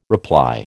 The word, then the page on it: reply: American English